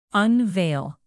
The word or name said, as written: unveil